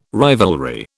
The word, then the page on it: rivalry: British English